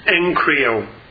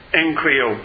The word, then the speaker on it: egchrio: The fifth Greek term is egg krio, E-G-C-H-R-I-O, Strong's 1472. It's actually pronounced Encrio, Encrio.